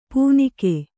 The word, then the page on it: ಪೂನಿಕೆ: Kannada